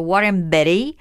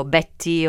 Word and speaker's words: Warren Beatty: È suo marito, qui scopriamo finalmente come si pronuncia, se qualcuno aveva dei dubbi, io li avevo, ed è appunto Warren Beatty e non Beatty o Betty o come uno potrebbe sbizzarrirsi.